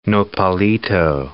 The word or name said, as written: nopalito